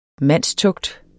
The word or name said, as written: mandstugt